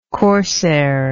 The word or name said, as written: corsair